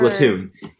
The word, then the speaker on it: Splatoon: Splatoon. It's pronounced Splatoon, please.